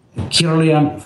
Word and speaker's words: Kirlian: Kirlian photography. – Okay. Is that, how is that spelled? K-E-U-L-I-N? – K-I-R-L-I-A-N. – K-I-R-L-I-A-N.